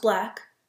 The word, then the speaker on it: 6LACK: Basically, one of my favorite artists, Black, um, you might see his name written as Six, written as Six Lack, um, but it's pronounced Black.